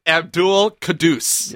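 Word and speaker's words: Abdul-Quddus: It's my favorite name on the Lions team now, Issa Abdul-Kaddous.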